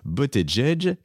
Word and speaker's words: Buttigieg: Le jeune espoir Pete, alors ça s'écrit Buttigieg, mais ça se prononce, vous me l'avez dit Laurence, Buttigieg, qui est le maire de South Bend en Indiana et qui est, lui, à 7%.